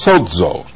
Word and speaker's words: sozo: The Greek verb for save that would correspond with Yahshua is sozo. That's spelled S-O-Z-O, but the Z is pronounced like a D is in front of it, sozo.